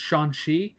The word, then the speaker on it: Shang-Chi: Then I have, after this, in July 9th of next year, Shang-Chi, actually I think it's pronounced Shang-Chi, as Kevin Feige said it, and The Legends of the Ten Rings.